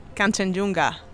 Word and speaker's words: Kanchenjunga: Kanchenjunga once.